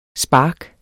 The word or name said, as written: spark